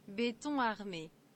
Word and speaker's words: Béton armé: And in case we mispronounced it, here it is. – Béton armé. – Nice. We've been screwing it up the whole time.